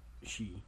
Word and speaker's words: Xi: I know his first name is X-I. – That's she.